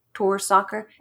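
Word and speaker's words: Torsåker: I think that's how you say it, Torsaker. It's T-O-R-S-A-K-E-R.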